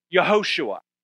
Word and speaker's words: Yehoshua: And that would be pronounced by us, Joshua. By them, they pronounced it Yehoshua.